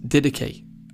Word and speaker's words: Didache: It's actually pronounced didache, and it's a Greek word that means training or teaching.